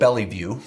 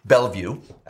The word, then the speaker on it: Bellevue: Some would think of that as Seattle, but if you know the Northwest area, it's spelled Bellevue, B-L-L-E-V-U-E, Bellevue. That's how it's pronounced.